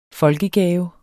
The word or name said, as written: folkegave